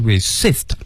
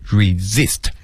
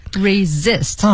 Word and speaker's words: resist: 好多朋友呢会把这个词读成 resist 啊，这个第一个 s 要读成 z，所以它读成的是 resist。 – resist 啊，那咱们一起来分析一下，这个里面有 s i s t。